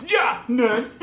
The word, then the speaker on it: JAWNERD: It is 405-JAWNERD, J-A-W-N-E-R-D, or if you want to pronounce those, JAWNERD, is how it's pronounced.